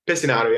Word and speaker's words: Pesinato: Pesinato, yeah.